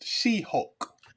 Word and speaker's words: She-Hulk: You mean She-Hulk, brother. I think her name is pronounced She-Hulk. I don't think she would appreciate being called Shulk.